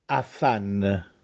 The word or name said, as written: Affànne